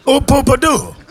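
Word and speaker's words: Ooh Poo Pah Doo: Cette chanson s'appelait Hoopa, je la prononce très mal, hein. – Hoopadoo. – Hoopadoo.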